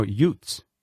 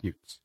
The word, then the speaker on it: Youts: And then we open that set with a brand new release, the song Memory Loss by the Youts. Let's hope I'm saying that right. Am I saying that right? – How is it spelled? – Y-O-U-T, or no, Utes. – Utes, probably Utes. – Utes. my bad.